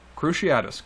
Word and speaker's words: Cruciatus: It's the Cruciatus mm-hmm. curse. – Cruciatus curse, mm-hmm.